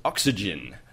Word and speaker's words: OXCGN: Um, our other favorite site, of course, is the Oz Xbox Community Gamer Network, um, otherwise known as Oxygen, which is spelt O X C G N.